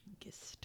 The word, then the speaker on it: Gist: And finally, if you want to get your spooky on, you can always visit the, I believe it's pronounced Gist Cemetery. – Gist. – G-I-S-T.